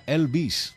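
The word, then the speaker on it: El Vez: Él se llama Elvez, pero si lo pronunciamos en inglés, sería Elvis. Este es el nombre artístico de un buen amigo como es Robert López.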